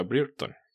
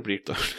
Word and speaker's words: Brereton: Descubrí que el apellido se pronuncia Brereton.